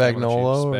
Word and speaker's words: Spagnuolo: How do you say the defensive coordinator? – Spagnuolo. – Spagnuolo.